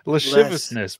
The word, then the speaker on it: lasciviousness: How do I say that word? Lavishness? – Lasciviousness, bro. – Lasciviousness? – Wait till you get the pirate, bro.